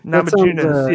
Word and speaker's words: Namajunas: So I guess this Rose Namajunas, I think is how you say it. – Namajunas, yes.